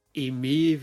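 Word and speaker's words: Eir: Deswegen war ich danach auch etwas sauer in dem Hinsicht, aber das Konzert dann, also das Sacra Music Konzert mit Eme, wie sie ja so richtig ausgesprochen wird, Riona und Aska, das war dann trotzdem gut.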